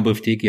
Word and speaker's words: Bifteki: Ja, und Bifteki hast du gerade richtig gesagt.